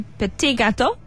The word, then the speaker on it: petit gâteau: For 5 days, immerse yourself in the art of French patisserie, mastering everything from the delicate opera to the perfectly glazed raspberry petit gâteau. Was it petit? – Petit gâteau. – Gâteau. – if you just say it like you're a little bit unsure, it sounds more French.